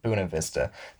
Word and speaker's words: Buena Vista: You know, like last summer, I worked at this farm in Buena Vista, Colorado, or as the locals call it, Buena Vista, because the German guy that found the place didn't know how to pronounce Buena. He said Buna.